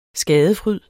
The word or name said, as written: skadefryd